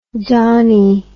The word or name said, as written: dawney